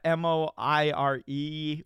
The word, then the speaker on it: Moiré: Is that how you pronounce it? – I call it strobing. – Yeah, Moiré with an accent, I believe, is the effect where it's like you've seen it.